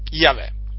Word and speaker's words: Yahweh: Qui il Signore manifestò al suo servo Mosè il suo nome, che è il tetragramma chiamato, che gli ebrei pronunciano con Yahweh in questa maniera.